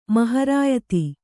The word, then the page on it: ಮಹರಾಯತಿ: maharāyati